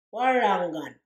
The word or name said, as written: warangan